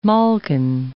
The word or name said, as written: malkin